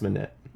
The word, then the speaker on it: Minnette: Monet. – How's it spelled? – M-I-N-N-E-T-T-E. – Minette. – Yeah, it's Minette.